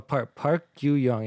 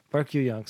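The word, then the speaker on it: Park Yoo-young: Oh, Park Yoo Young. Yeah, I'm pronouncing it properly. Park Yoo Young.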